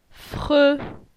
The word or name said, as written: Fre